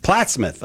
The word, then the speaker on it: Plattsmouth: Wait, how do you say it? – Plattsmouth. – Okay, yeah. – But yeah, Plattsmouth, same thing, Plattsmouth.